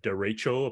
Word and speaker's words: derecho: We had a derecho, apparently that's how you pronounce it, a derecho storm.